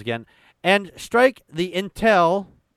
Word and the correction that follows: lintel: Lintel. I'm sorry. That's an L.